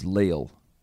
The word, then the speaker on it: Leal: My first name is pronounced like Neil, but it's Leal.